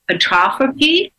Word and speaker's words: atrophy: We want to get out of the old rut of not feeling good and seeing muscle atrophy. How do you say that? – Atrophy. – Atrophy. Okay.